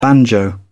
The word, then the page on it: banjo: British English